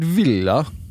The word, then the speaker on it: Villa: David Villa.